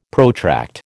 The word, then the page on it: protract: American English